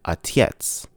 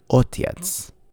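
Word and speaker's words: otyets (отец): Notice how in the Russian pronunciation of our sample, this sounds more like atyets rather than otyets.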